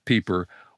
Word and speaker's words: Piper: His name is spelled Piper, but the German pronunciation, Otto Pieper, was a realist.